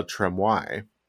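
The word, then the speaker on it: Trémoille: And by the way, I've been pronouncing his name wrong. – Tremouille. – I've been saying Tremouille. It's actually Tremouille. – Tremouille. – I was pronouncing it like Gremouille, which means frog in French.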